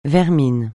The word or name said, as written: vermine